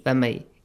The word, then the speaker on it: فَمَن يَعْمَلْ: So how do you say this? – فَمَي. – Is it idgham with غُنَّة or without غُنَّة? – Without غُنَّة. Because it's the letter ya, yes? – Yes.